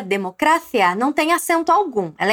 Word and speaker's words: democracia: Mas nas vezes que eu falei democracia, você percebeu que a sílaba tônica dessa palavra foi cra, democracia.